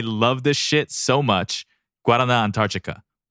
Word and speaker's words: Guaraná: You were really pronouncing the fuck out of that. – Guaraná Antarctica. – You'll follow un poco your Portuguese. – Antarctica. Oh, okay. – Wow, that's right. Impressive.